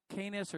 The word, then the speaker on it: Canis: It outshines every other star in the Milky Way, and it is located in the, I think they pronounce it Canis or C-A-N-I-S, major constellation.